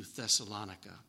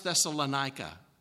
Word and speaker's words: Thessalonica: Don't yet know how to pronounce it. What is your vote? Because we're going to be saying it all week long. Who likes Thessalonica? Who doesn't? Anika, I think that's actually, I've been there, and that's how they say it.